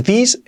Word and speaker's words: this: Y la I no es this, es una E.